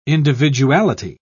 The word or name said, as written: individuality